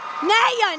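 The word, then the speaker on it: Nayyar: Kunal Nayar. Oh, no, no, no. Nayar, Nayar, Nayar. Kunal Nayar.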